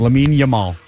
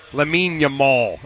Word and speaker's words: Lamine Yamal: Lamine Yamal. – Lamine Yamal.